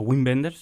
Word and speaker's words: Wim Wenders: Y es una película de un director que de Wim Wenders, o Wim Wenders, se, escribe con W, pero se pronuncia con V.